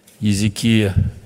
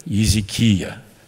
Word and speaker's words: Езекия: Да, вот, оказывается, так произносится имя этого царя Езекия. Мы сейчас записываем аудиоверсию Библии. И получается, что большей части вот, еврейские слова, еврейские имена имеют ударение именно вот на этом слоге.